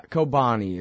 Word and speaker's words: Kobani: I said Cobain. Yeah, sorry. I don't know. All right. Sorry, I got a computer technical difficulty that I'm screwing up over here. Not your fault. Yeah, Kobani is how it's pronounced.